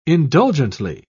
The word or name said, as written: indulgently